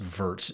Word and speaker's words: Virts: What you said reminded me of, there was an astronaut named Terry, I think it's pronounced Virts, V-I-R-T-S, who was spent like 200 days in space.